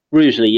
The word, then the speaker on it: Rugeley: You were in the Amazon warehouse in, is it Rugeley? Is that how you say it? – Rugeley, yeah, that's right.